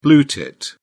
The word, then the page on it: blue tit: British English